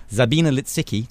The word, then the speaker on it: Lisicki: And Sabina Litsicki, to pronounce it properly, beat Agnieszka Radwanska.